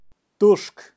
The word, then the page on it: turk: Kaqchikel